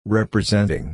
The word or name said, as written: representing